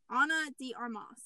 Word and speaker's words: Ana de Armas: Well, speaking of which, Ben Affleck and Anna de Armas. How do you say her name? Anna de Armas.